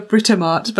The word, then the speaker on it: Britomart: I want to call her Britomart, but I feel like that sounds like a supermarket.